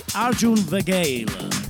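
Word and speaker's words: Arjun Vagale: Io lo pronuncio così, Arjun Vagail.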